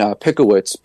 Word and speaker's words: Pickowicz: I'll tell you, but my name is Nate, N-A-T-E, and then Pickowicz, P-I-C-K-O-W-I-C-Z. I tell people you can pick your friends, but you can't pick your wits, so that's how you pronounce my last name, Pickowicz.